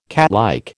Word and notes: catlike: American English

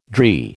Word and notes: dree: American English

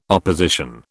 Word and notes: opposition: British English

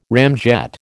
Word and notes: ramjet: American English

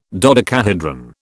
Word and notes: dodecahedron: British English

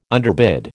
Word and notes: underbid: American English

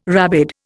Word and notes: rabid: Indian

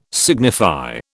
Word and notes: signify: British English

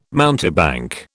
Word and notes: mountebank: British English

